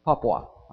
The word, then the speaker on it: Papua: In September the 22nd, 2011, just a couple days ago, Paul Westlund, over in Indonesia, flying in Paul, Indonesia. Paul, P-A-P-A-U, is that right? Paul, is that the way you pronounce it? Papua, okay? Papua, Indonesia.